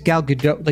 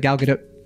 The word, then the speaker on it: Gal Gadot: And I think it's Gal Gadot. Like, you're not supposed to do the emphasis on the T. – Okay, That's what I picked up. It's like Gal Gadot. You got to do it quick and soft.